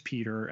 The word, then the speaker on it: Pieter: For instance, my name is Peter, and though it is spelled in the Dutch tradition, P-I-E-T-E-R, the I is silent.